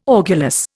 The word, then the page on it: orgulous: Indian